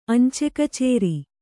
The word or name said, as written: ಅಂಚೆಕಚೇರಿ